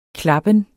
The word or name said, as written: klappen